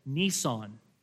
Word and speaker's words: Nisan: But here, the Lord starts their calendar with the Jewish month of Nisan. Nisan, it sounds like the car company. It's spelled the same, just with one S, Nisan. It's basically the month of March for us.